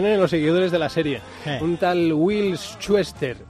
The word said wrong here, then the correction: Schuester: A ver, ¿cómo, lo has, pronunciado? Will Schuester. Ah, Schuester. – Schuester, como Schuster en Netflix, en Netflix eh, me pues están diciendo que, que la echan.